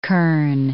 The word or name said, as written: kern